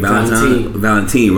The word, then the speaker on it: Valentin: Valentin, right? – Yeah.